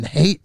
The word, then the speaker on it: Haight: So Richard, R-I-C-H-A-R-D, the letter L, Richard L, and then hate is spelled H-A-I-G-H-T.